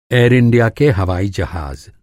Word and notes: हवाई-जहाज़: ہوائی جہاز

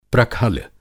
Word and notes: प्रखल: Hindi

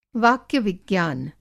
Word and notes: वाक्य-विज्ञान: Hindi

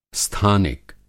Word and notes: स्थानिक: Hindi

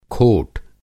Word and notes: खोट: کھوٹ

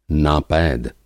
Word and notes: नापैद: Hindi

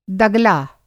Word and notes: दगला: Hindi

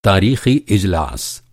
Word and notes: इजलास: اِجلاس

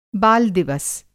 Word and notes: बाल-दिवस: Hindi

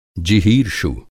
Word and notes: जिहीर्षु: Hindi

